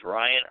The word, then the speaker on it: Ryan: My name is Ryan, R-Y-A-N. Last name is A-D-R. A-D-N-A.